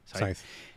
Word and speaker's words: Scythe: And the last thing I'll say is my main video I put up was I finally got like the Sith or Scythe, how you say it? – Scythe.